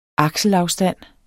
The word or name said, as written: akselafstand